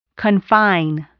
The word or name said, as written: confine